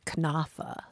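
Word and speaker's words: cnafa: In Old English, they would call boys knaffa. – Knaffa, yeah. And that's the old pronunciation of the word, right? – Yeah.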